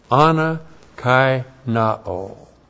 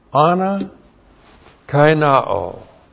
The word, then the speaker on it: anakaino: Ana kai na'o. This is why you're here. A-N-A-K-A-I-N-O, long O. Ana kai na'o.